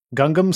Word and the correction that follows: Gungan: style? Is that how you pronounce it? Gungam? – Uh, Gungan style. Gung- it's Gungan like style.